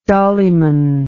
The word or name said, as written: dollyman